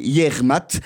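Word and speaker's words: Yec'hed mat: « Yermat », ce qui s'écrit « Yesh Eud Mad », mais d'après ce qu'on m'a appris, on dit « Yermat ».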